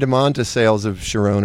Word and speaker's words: Charon: Everybody needs to hear the song "Sales of Sharon." How do you say it? – Sharon.